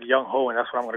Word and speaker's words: Younghoe: I found this out today that it's pronounced Younghoe. It's spelled Younghoe. And I was saying that all throughout the postgame yesterday, so excuse me. But apparently his name is really pronounced Younghoe Koo.